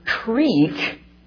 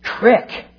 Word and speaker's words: creek: But Pittsburghers are also betrayed by their indistinguishable pronunciation of the words R-O-L-L, R-O-L-E, and R-U-L-E. That's something I actually practice. I'm getting better, I think. But worst of all, native Pittsburghers pronounce the word creek, crick.